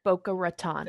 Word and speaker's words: Boca Raton: What is it? – Boca Raton. – Thank you. – It's Boca Raton. – Yeah, either way, I said it wrong. – I was there. – I said it completely wrong.